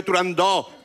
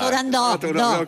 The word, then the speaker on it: Turandot: Yo también lo digo mal. Si es que son muchos años diciéndolo mal. En el mundo exótico en el que vive Turandot, con O abierta. – Turandot, con O abierta, ¿vale?